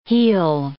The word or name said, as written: he'll